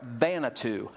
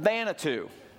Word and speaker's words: Vanuatu: The little country of about 200,000 people that was rated at the top of that list was the country of, I want to make sure I pronounce this right, Vanuatu, not Xanadu, but Vanuatu.